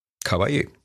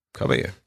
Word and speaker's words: Caballé: Ich kann ihren Namen nicht aussprechen. Montserrat Caballé? Caballé. Ja. Caballé. Genau.